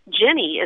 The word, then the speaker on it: Ginny: Jenny is spelled G-I-N-N-Y, and it comes from the name Virginia.